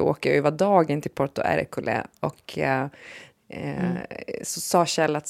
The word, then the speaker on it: Porto Ercole: Porto Ercole.